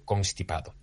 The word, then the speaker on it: constipado: constipado. Pues bien, lo adecuado es pronunciar la N y por tanto no es constipado.